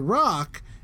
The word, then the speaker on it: Rok: Now, The Rock is spelled R-O-K.